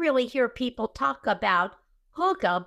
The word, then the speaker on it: hygge: Hygge, which is H-Y-G-G-E. Can you, Bill, help me out. Can you spell that into chat for me? It is pronounced who, H-O-O, ga, G-A-H. Now, when I went looking for how do you pronounce it, some of them said Hygge, like the O-O with the vowels, you know, the short vowel. And others said Hygge, which the long vowel.